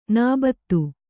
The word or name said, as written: ನೌಬತ್ತು